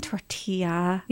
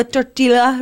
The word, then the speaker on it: tortilla: Oh, the L is silent. – I'm not saying tortilla.